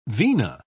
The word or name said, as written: vena